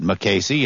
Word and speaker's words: Mukasey: I guess he'd pronounce it Mukasey. It's M-U-K-A-S-E-Y. Looks like Mukasey to me.